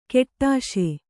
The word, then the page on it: ಕೆಟ್ಟಾಶೆ: keṭṭāśe